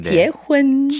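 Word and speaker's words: 지혜혼 (结婚): 결자를 중국어로 지혜라고 발음해요. 네. 지혜혼.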